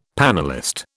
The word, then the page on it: panellist: British English